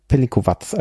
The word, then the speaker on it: Pelinkovac: Und der wird, also jedenfalls hier auf der Flasche, ohne, ja, ja, ich glaube, das ist richtig, dass da kein Akzent, also Pelinkovac, also das ist jetzt ein Beispiel dafür, dass das C hinten wie ein Z ausgesprochen wird.